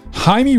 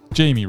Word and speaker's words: Jaime: Blue Beetle is the story of Jaime Reyes, or Jamie Reyes, depending on how you're pronouncing it. But I'm going to go with Jaime.